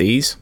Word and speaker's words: Ys: I think it's pronounced Ys, those games.